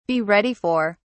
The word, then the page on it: be ready for: British English